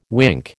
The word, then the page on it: wink: American English